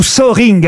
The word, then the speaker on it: Soehring: C'est son vrai nom, Hans-Jürgen Zöring. – Il vit en France ou Zöring, hein, vous prononcez comme vous voulez. Ça s'écrit S-O-E-H-R-I-N-G.